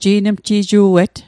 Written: ji'nmji'ju'et

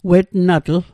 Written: wetnatl

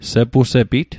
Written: sepusepit

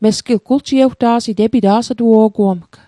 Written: guljiewta'sit

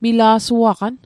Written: mila'suaqan

